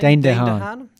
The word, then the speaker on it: Dane DeHaan: Dane DeHaan.